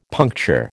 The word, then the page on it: puncture: American English